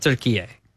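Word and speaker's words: Türkiye: They pronounce it turkey A. – Turkey A. – It's pronounced turkey A.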